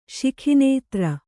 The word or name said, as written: ಶಿಖಿನೇತ್ರ